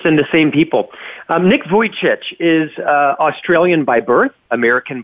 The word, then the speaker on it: Vujicic: How do you pronounce his last name? – Vujicic. Yes.